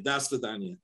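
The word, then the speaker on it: Das Fedonia (до свидания): Das Fedonia. – Okay. Yep. – Yeah. More A's than O's.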